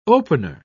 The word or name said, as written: opener